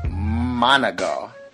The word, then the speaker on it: Monongah: Monongah. That's how you pronounce that city.